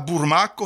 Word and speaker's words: bormaca: Alors là, burmaco, ça s'écrit bormaca.